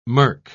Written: murk

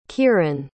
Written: keiran

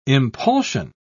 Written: impulsion

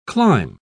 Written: climb

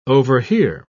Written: overhear

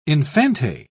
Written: infante

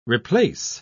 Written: replace

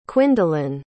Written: quindalyn

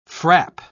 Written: frap

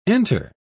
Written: enter

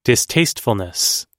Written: distastefulness

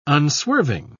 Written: unswerving

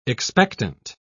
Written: expectant

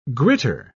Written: gritter

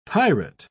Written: pirate

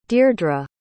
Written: deirdra

